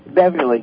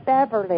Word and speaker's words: Beverley: No, the first name is sufficient, but would you pronounce that so I didn't quite understand her first name? – Beverly. B-E-V-E-R-L-E-Y. – Oh, okay, Beverly.